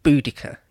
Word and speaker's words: Boudica: You're supposed to say Boudica now. Apparently, that is the agreed thing.